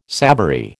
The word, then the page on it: savoury: American English